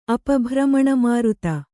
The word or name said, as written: ಅಪಭ್ರಮಣಮಾರುತ